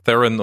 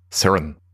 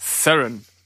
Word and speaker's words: Theron: Ja, weil äh, sie wird nicht Englisch äh, Theron oder Theron ausgesprochen, sondern sie ist ja aus Südafrika und da spricht man das eben Theron aus. Theron.